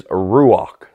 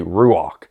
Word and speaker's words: Ruach: The word, by the way, is Ruach. Say Ruach. It's spelled different ways, but I've learned how to spell it R-U-A-C-H. And you have to clear your throat, you know, when you say Ruach.